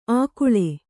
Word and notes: ಆಕುಳೆ: ākuḷe

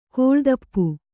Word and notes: ಕಾಲುದೀಪ: Kannada

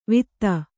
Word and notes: ವಿತ್ತ: Kannada

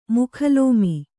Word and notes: ಮುಖಲೋಮಿ: mukha lōmi

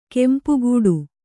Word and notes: ಗುಂಪುಗೂಡು: gumpugūḍu

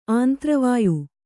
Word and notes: ಆಂತ್ರವಾಯು: āntravāyu

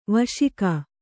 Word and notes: ವಶಿಕ: vaśika; Kannada

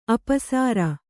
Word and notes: ಅಪಸರ: apasara

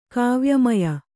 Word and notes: ಕಾವ್ಯಮಯ: Kannada

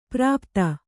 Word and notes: ಪ್ರಾಪ್ತ: prāpta

